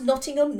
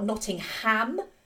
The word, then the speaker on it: Nottingham: And obviously the song Not in Nottingham as well, which is pronounced Nottingham, not Nottingham, like the movie seems to suggest it is. But obviously that is a product of having Americans say Nottingham, because Americans emphasise the ham in these towns and cities. And no, it's just Nottingham. That's just how you say it.